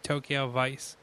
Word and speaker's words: Tokyo Vice: Every time I say Tokyo, I always want to say Tokyo Voice, and I fuck it up most times. Like, I really have to try to say it right. Tokyo Vice.